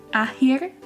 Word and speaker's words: akhir: Akhir. – Akhir. A-H-I-R? No. – A-K-H-I-R. – Oh. – Akhir. – I didn't hear that part. I just heard Akhir. – Yeah, but the K is silent.